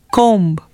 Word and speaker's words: comb: In the words that you are going to hear now, the letter B is silent when it appears with M, like womb, tomb, bomb, climb, comb.